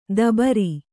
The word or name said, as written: ದಬ್ಬರೆ